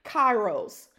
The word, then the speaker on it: Kairos: This is actually a Greek word. It's pronounced Kairos, and it's spelled K-A-I-R-O-S.